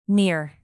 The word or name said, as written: near